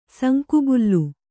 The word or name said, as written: ಸುಂಕುಬುಲ್ಲು